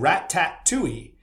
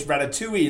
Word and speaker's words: Ratatouille: On my first ever TikTok video, where I was appealing to be the producer of Ratatouille the musical, and I pronounced Ratatouille wrong. I said Rat-tat-touille. And you should have, listen, the one thing you don't want to do on TikTok is outrage Gen Z, because they are brutal and they will come after you, right? So I had so many comments, you pronounced it wrong. How dare you? You pronounced it wrong. It's Ratatouille. It's not Ratatouille, you old man, like all that stuff, right?